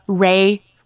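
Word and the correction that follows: Rai: which I actually did enjoy. Rye. – Yes. Like the bread.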